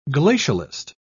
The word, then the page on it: glacialist: British English